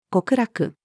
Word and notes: 極楽: female

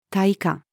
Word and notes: 大家: female

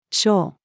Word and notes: 賞: female

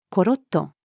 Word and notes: ころっと: female

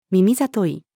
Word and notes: 耳聡い: female

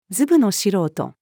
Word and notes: ずぶの素人: female

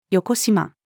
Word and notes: 邪: female

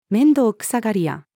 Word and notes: 面倒くさがり屋: female

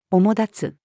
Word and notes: 主立つ: female

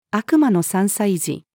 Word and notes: 悪魔の３歳児: female

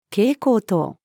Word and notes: 蛍光灯: female

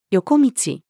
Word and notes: 横道: female

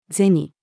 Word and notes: 銭: female

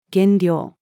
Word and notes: 減量: female

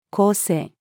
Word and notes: 構成: female

